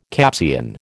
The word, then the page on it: Capsian: American English